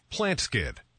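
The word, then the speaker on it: Plantskydd: Plant Skid. That's spelled plant, S-K-Y-D-D. Plant Skid.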